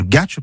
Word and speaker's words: gache: La forme la plus courante qu'on trouve dans le dictionnaire de l'Académie ou chez Blanchet, c'est Gache-Tirampeg, sans circonflexe sur le A, parce que ça vient pas du français gâché, mais du provençal gatch. Et méfie donc de bien dire gatch, parce que sinon ça fait une prononciation à la Gachampeg.